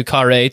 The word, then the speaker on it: Carrhae: It's C-A-R-R-H-A-E, so we're going with Carrhae.